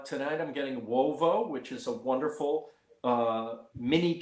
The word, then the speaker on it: uovo: Wovo means egg in Italian. U-O, V-O, Wovo.